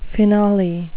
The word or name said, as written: finale